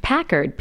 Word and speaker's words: Packard: It's Packard, P-A-C-K-A-R-D. So, like, think Alice with a Y-N, A-L-I-C-Y-N.